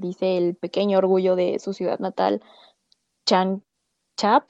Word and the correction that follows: Changsha: perdón por la pronunciación, pero en chino es Changsha Entonces, si lo dije mal, pues ahí díganme cómo se dice.